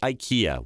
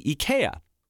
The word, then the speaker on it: IKEA: Take IKEA, which most of us pronounce incorrectly. I just did. It's actually IKEA.